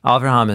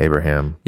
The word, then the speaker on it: Avraham: Avraham is how you say. – Avraham, Abraham.